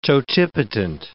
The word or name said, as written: totipotent